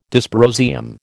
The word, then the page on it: dysprosium: American English